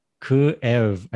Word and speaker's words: Kyiv: But Kyiv is wrong and Kiev is the Russian way. So both are wrong. It's kind of a double syllable there. – It's Kyiv.